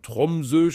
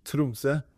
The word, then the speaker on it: Tromsø: Und ich war schon völlig geschockt, als ich jetzt festgestellt habe, ich habe immer Orte allein falsch ausgesprochen. Also es das heißt gar nicht, wenn ich immer so von Tromsø spreche. – Ja. – Ist gar nicht richtig. – Tromsø.